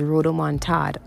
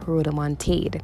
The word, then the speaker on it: rodomontade: The word of the day today is rodomontade or rodomontade. It is spelled R-O-D-O-M-O-N-T-A-D-E and it is a noun.